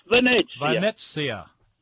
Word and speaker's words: Venezia: I thought the name of the place was Venezia's, and you pronounce it almost perfectly. Venezia. Venice.